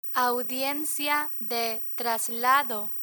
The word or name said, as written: audiencia de traslado